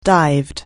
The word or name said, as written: dive